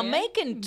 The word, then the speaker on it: Macon: So she's in Macon, Georgia. Sounds like bacon, if you guys needed that explanation. – Oh, what's wrong? – Go on. – Go on. – Now, Macon does rhyme with bacon, if y'all misheard me.